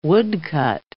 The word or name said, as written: woodcut